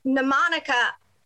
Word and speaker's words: mnemonic: Which, PSA moment, it is mnemonic. – The M is silent. – Mnemonica. Yeah. So it's, the M is silent and it's not new. There's no U.